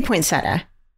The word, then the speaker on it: poinsettia: Well, there's no Z in there. It's an S. – Poinsettia or poinsettia? – I say poinsettia.